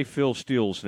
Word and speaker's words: Phil Steele: Yeah, the way he said it. The way I say it sounds like S-T-I-L-L. Phil Steele. Yeah, kind of a double E. But now Phil Steele is a tremendous resource for college football fans.